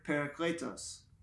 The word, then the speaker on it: parakletos: That Greek word is the word parakletos, which sounds much more Greek than Italian, yes, the way I just said that.